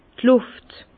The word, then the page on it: d' Lùft: Bas-Rhin; Herrlisheim